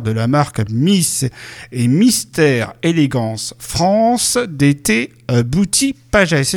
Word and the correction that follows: Beauty Pageant: Est-ce que j'ai bien prononcé, monsieur Bruno Solari? – Alors, on va reprendre Miss et Mister Élégance France d'été Beauty Pageant. – Voilà, pageant. D'accord.